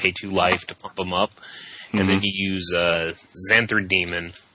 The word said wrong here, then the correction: Xathrid Demon: Why does everybody pronounce that card wrong? – Oh, it's Xathrid Demon. – There is no N in it. Just FYI.